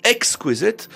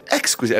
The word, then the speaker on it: exquisite: Je me souviens qu'une fois, elle me disait « Ah, j'ai découvert qu'en fait, l'adjectif « exquisite », c'est la première syllabe qui est accentuée, et non la seconde. « Exquisite », alors elle était ravie, elle disait « exquisite ».